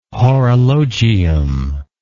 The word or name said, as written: horologium